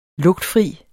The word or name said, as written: lugtfri